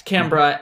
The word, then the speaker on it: Canberra: Like, as US, a better example is Australia designating Canberra. I said that right? Yeah, Canberra as like its own like official thing that isn't part of any of the other states of Australia.